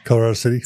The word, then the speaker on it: Colorado City: Colorado Spring City. I always say it wrong. – Colorado City. – So okay, yeah. Colorado City.